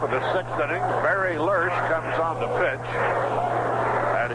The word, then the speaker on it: Lersch: Name is spelled L-E-R-S-C-H. Lurch, Larry Lurch.